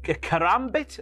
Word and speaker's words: karambit: Kong and Rhodes made their way to the ring, assaulting Priestley and cutting off a lock of hair with a karambit. Is that how you pronounce it? – Karambit. – It's a curly knife. – Yes.